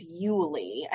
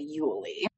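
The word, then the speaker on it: Uly: And one is a little female fox named Mia, and another is a little male fox named Yuli. I hope I'm pronouncing that correctly. It's U-L-Y. So I'm going to say Yuli.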